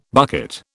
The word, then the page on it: bucket: British English